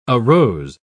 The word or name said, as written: arose